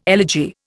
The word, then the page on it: elegy: Indian